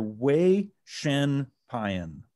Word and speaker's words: Vaishampayan: I actually got a lesson from Dr. V, and she says it is Dr. Vaishampayan.